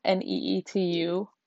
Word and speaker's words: Neetu: So it's spelled Neetu, but you'd be surprised how many Finnish people think it's spelled with I's.